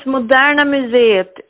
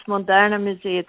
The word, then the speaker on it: Moderna Museet: No, it's Moderna Museet. It's not the Museum of Modern Art of Stockholm, which many people would presume because that's what it is. But the name is Moderna Museet. It's like a trademark. So the pronunciation is like in Swedish, but in English, if you understand.